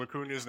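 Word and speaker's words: Acuña: And he's saying Ronald Acuna's name wrong, and he's like mouthing him, it's Acuna.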